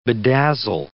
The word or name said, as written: bedazzle